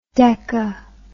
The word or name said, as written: deca